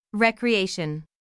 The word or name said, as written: recreation